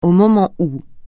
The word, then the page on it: au moment où: francais